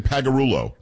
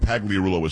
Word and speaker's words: Pagliarulo: It's only four syllables when Pagliarulo is five. – It's the Leah.